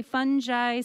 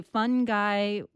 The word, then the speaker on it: fungi: You say fungi, some say fungi. What's the difference? – Yeah, there's a bit of a cultural thing, I think. The people at Oxford say it one way and the people at Cambridge say it another way. But the Oxford people tend to be, in the Oxford English Dictionary, they go with both pronunciations. So both are considered correct according to that source anyway.